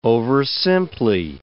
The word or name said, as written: oversimply